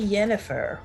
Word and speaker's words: Yennefer: Why with the Yennefer? Why not just say Jennifer? Why? – Poland. – Because Yumpin' Yemeni, that's how it's pronounced over there. – It's spelled with a Y.